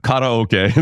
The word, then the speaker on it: karaoke: I can't say karaoke, uh, especially to you, but karaoke is how it's pronounced, folks. I don't know how we got karaoke out of those letters, but they were asked to do this.